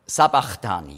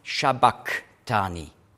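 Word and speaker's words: sabachthani: Also nicht Sa, sondern nicht Sha, sondern Sa. Und das Chi, das griechische CH, hat man ausgesprochen K. Also nicht Ch, wie Schweizerdeutsch. Chuchichastli, sondern K. Und das entspricht dem Q im Aramäischen Shabaktani.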